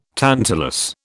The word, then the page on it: tantalus: British English